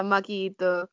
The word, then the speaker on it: Maki Itoh: You versus uh, Maki Itoh. I hope I said that right. – Yeah, Maki Itoh.